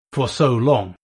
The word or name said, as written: for so long